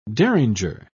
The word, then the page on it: derringer: British English